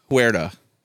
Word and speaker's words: Huerta: Tenoch Huerta, I think his name is. But I don't know if I'm saying that correctly. Tenoch Huerta.